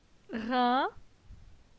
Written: Rin

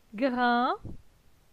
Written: Grin

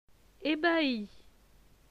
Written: Ebahi

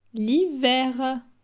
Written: L'hiver

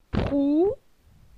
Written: Prou

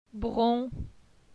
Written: Bron

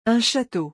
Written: Un chateau

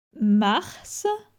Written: Mars

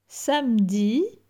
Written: samedi

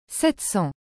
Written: 700

